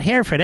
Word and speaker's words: Hereford: Is it pronounced Hereford, sir? – No, it's Hereford. – That's what I thought, Hereford. Everybody knows it's Hereford, Rich. – Yeah, it's like H-I-R, but it's not H-E-R-E, but it's Hereford. – It's spelled like, it sounds like H-A-I-R. Is that correct, sir? – Yeah, correct.